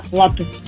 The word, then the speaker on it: латте: И знаете, это правильно, потому что слово итальянское, ударение там стоит на первый слог, латте. И вот, несмотря на обилие гласных, произносится твердо, без мягкости, латте.